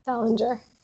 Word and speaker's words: Salinger: Is that how you say it in English? – Salinger.